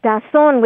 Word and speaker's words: Tazón: Calzón? – Tazón with a T, as in Tom.